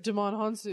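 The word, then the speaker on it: Djimon Hounsou: Was it Damon Hanzu? – Yes. – I can never say his name right. – Damon Hanzu.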